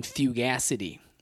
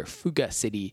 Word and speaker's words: fugacity: First, F-U-G-A-C-I-T-Y, so it looks like Fugacity, but fugacity, I assume, is how we're pronouncing it.